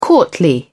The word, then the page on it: courtly: British English